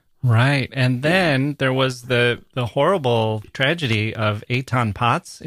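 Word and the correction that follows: Etan Patz: Eitan Pates.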